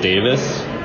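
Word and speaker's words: Ben Davies: Ben, so I'm pretty sure this is how it's actually pronounced, Davis, even though it looks like Davies for Americans out there.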